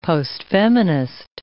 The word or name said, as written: postfeminist